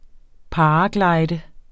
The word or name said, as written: paraglider